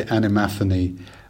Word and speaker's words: animaphany: I would say animaphany.